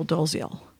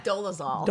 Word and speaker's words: Dolezal: is dating Rachel Dolezal. So Dolezal. – Dolezal? Damn it. I knew I was getting her name wrong.